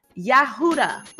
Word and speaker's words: Yehuda: Judah, Yehuda, that's how they pronounce it.